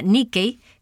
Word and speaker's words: Nike: La palabra Nike, que se deletrea N-I-K-E, pero se pronuncia Nikei, que en griego significa conquista o victoria. Y la otra es la palabra Nikao, N-I-K-A-O, Nikao, que significa dominar, prevalecer, triunfar o conquistar.